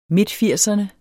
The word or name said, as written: midtfirserne